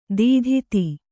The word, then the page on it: ದದಾತಿ: dadāti; kannada